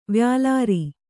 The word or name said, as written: ವ್ಯಾಲಾರಿ